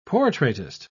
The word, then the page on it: portraitist: British English